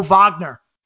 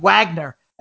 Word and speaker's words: Wagner: Last game, the other guy is Mo Wagner, right? Now that's another pronunciation for you. His last name is spelled like Wagner, and it's pronounced Moritz Wagner with a V.